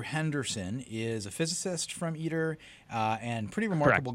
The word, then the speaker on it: ITER: Did I pronounce that correctly, Jay? – Yep, I've been saying ITER forever. I apologize to all the ITER people.